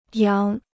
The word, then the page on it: 扬: yáng